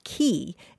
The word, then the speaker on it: quay: And so, David, yeah, the word spelled Q-U-A-Y is usually pronounced key. It came into English in the 1300s. spelled K-E-Y, but then it was changed to the French spelling, which was Q-U-A-Y.